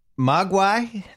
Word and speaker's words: Mogwai: Another one that's aged the best, Mogwai.